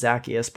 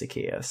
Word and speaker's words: Zaccheaus: I thought it was Zaccheaus, by the way, all week last week, but apparently it's Zaccheaus. So definitely pronounced that wrong like 100 times, but you know, let's talk about it.